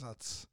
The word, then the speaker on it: Utz: It is Utz.